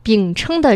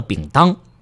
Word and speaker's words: 饼撑: 饼撑的撑啊，哟，您问了一个特别好的问题。 – 有人不认识那个字儿，给读成了饼当。